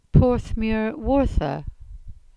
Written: Porthmeur Wartha

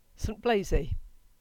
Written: St Blazey

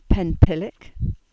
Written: Penpillick

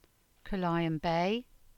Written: Carlyon Bay

